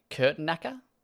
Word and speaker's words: Kurtenacker: Jeff Kurtenacker? – That's correct. Yeah, that's correct. He's an American, so you can pronounce it just like you would.